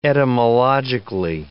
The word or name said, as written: etymologically